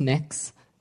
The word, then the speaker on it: Mx: It's pronounced mix.